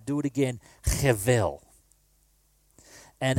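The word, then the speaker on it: hevel (הבל): the Hebrew word hevel, spelt with a B, pronounced with a V. And I want you to say that word with me with some hearty Yiddish nasal action, hevel.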